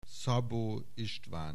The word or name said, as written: Szabó István